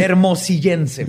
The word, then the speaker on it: Hermosillense: Espérate, ¿dijiste hermollicense? – Se dice así. Hermosillense, perdón. – No, es que me quedé trabado ahí. Hermosillense. – Es hermosillense.